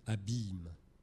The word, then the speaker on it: abîme: Donc en grec, abyssos, transcrit comme ça en caractère romain, se prononce déjà abîme comme aujourd'hui, mais se prononçait en fait abîme, avec un i long, abîme.